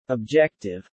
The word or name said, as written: objective